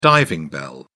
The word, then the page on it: diving bell: British English